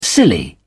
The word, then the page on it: silly: British English